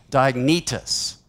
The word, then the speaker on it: Diognetus: It's a long E, Diognetus.